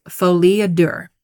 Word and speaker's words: folie à deux: I could be mispronouncing it, but I did Google it to try to figure out how to pronounce it. So, I think that's right. Folie à deux. It's French.